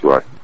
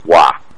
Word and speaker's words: Roy: It's pronounced Roy. – I remember Ken Dryden. – Patrick Wah.